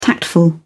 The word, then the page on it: tactful: British English